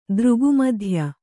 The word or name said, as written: ದೃಗುಮಧ್ಯ